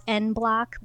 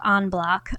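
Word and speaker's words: en bloc: It kind of looks like it should be pronounced N-block, but because it's E-N, but it's pronounced on-block, otherwise known as total capsulectomy service.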